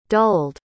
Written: dulled

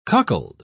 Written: cuckold

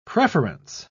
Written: preference

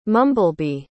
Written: mumblebee

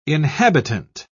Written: inhabitant

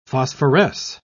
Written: phosphoresce

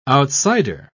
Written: outsider

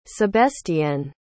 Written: sebestienne

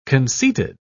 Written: conceited

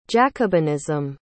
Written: jacobinism

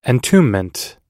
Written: entombment

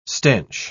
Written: stench

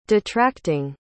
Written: detracting

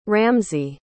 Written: ramsay

